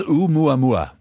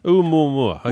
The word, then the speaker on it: Oumuamua: Now, tell me about the attempts to contact Oumuamua. Is that how you pronounce it? – It's Oumuamua. – Oumuamua.